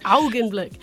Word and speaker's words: Augenblick: Augenblick.